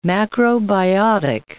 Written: macrobiotic

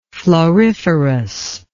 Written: floriferousness

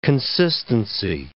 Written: consistency

